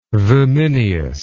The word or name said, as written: vimineous